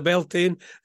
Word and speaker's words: Beltane: So is that pronounced Beltane? It's not pronounced Belen. – No, it's definitely Beltane, because there's a Jethro Tull song that's a chorus. – I don't understand why the A-I-N in that is pronounced Ain, but it's not in Samhain.